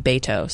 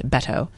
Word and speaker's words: Beto: By the way, I listened to reader feedback that said I was pronouncing it extremely white and it's saying Beto. So I'm taking the critique and pronouncing it to Beto, which I hope is better.